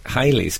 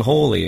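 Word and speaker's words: Halley: Yeah, but only in recent years they've started calling him Hawley again.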